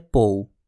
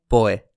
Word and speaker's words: Poe: Ξέρω πάρα πολύ καλά ότι η αγγλική προφορά του ονόματος είναι «πόου». Αλλά στα ελληνικά τον λέμε «πόε».